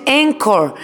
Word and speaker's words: Anchor: así se escribe la aplicación del podcast, pero se pronuncia Anchor.